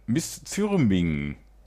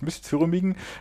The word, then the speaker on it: Misþyrming: Black Metal aus Island von Miss Zürming. Ich hoffe, ich habe es richtig ausgesprochen. Es ist dieses isländische P, das man aber wie ein englisches TH ausspricht. Miss Zürming, das neue Album Med Hamri.